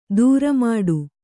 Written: ದ್ರಾಮಿಡಿ

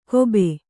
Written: ಖೂಬ

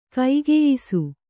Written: ಕೈಗೆಯ್ಸು

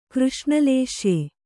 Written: ಕೃಷ್ಣಲೇಶ್ಯೆ

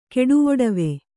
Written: ಕೆಡುವೊಡವೆ

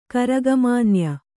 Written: ಕರಗಮಾನ್ಯ

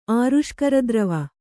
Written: ಆರುಷ್ಕರದ್ರವ

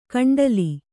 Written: ಗುಂಡಲ

